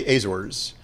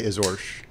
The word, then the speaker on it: Azores: Well, in the United States, we say Azores. – Okay. – But in Portugal, they would say Azores.